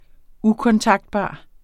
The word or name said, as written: ukontaktbar